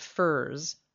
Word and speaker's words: furze: As a quick aside, the poet uses the word furs a few times. It's spelled F-U-R-Z-E, and it refers to an evergreen shrub.